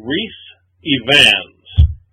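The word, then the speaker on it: Rhys Ifans: Yes, into the next actor, who it took me a long time to figure out how to pronounce this guy's name, but it's apparently Reese Evans.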